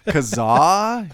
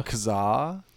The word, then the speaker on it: Kazaa: Kazaa, if you're an asshole. I always said Kaza. I didn't like to pronounce the second A. – Kaza? – Kazaa?